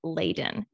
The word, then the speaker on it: Leiden: Um, also, it's pronounced Leiden University.